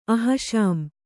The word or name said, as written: ಅಹಷಾಮ್